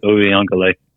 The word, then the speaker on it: Uiagalelei: You know, they lost 10-3, but DJ Uiagalelei, I believe is how you pronounce it. – Uiagalelei.